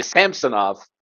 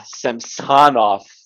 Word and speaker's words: Samsonov: Which, that pronunciation is always going to fuck me up, because I'm used to Sergei Samsonov, where you put the emphasis on the different syllable there. So every time I hear Samsonov, I always think that it's being pronounced incorrectly.